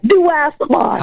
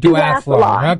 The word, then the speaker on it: duathlon: How do you do that? – Duathlon. – All right, duathlon.